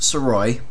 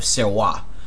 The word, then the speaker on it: Sirois: Saroy, S-I-R-O-I-S. Just to let you guys know, It is pronounced Saroy. Just take a look at the way that the name is spelled and then just take off that last S. The last S is silent. Now that is the Americanized version of how my name is pronounced. It's also pronounced in the French-Canadian way of Serrois.